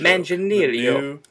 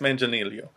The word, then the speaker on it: Manganiello: Am I butchering that? Anyway, Joe Manganiello has been casted as Deathstroke. – Manganiello. – Yes, Manganiello.